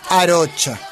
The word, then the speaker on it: Arocha: Arocha. – Ahí lo pronunciaste bien vos. – No, como, aparte como él dice que es y yo, si él dice que es Arocha, será Arocha.